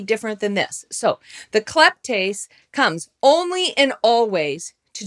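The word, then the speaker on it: kleptes (κλέπτης): The Greek word is literally kleptes, or as the guy says it when you have him pronounce it, kleptes.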